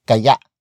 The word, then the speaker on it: kaya: These feasibility questions feature the word kaya. Kaya Kaya. Kaya is spelled as K A Y A. Note the glottal stop at the last vowel.